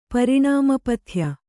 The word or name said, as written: ಪರಿಣಾಮಪಥ್ಯ